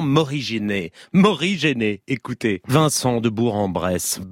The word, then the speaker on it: Bourg-en-Bresse: il faut bien prononcer Bourg-en-Bresse, il poursuit, c'est une déformation locale patoisante qui fait persister Bourg.